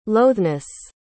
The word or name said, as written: loatheness